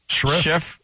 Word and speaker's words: Scherff: Am I saying that right? Scherff? – Scherff. – He's Scherff.